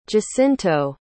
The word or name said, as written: jacinto